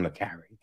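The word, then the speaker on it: Le Carré: I, I always say John Le Carre. I've heard it pronounced other ways, but fuck other people. It's John Le Carre.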